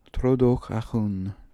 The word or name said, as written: trodokhakhoon